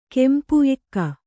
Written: ಎಕ್ಕಾ

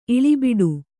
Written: ಇಳಿಬಿಡು

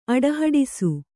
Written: ಅಡಹಡಿಸು

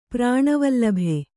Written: ಪ್ರಾಣವಲ್ಲಭೆ

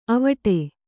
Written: ಅವಟೆ

